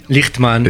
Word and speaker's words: Lichtman: Aviv Lichtmann, je prononce bien Lichtmann. Lichtmann, ah bah oui. Bien, avec avec la petite gutturale.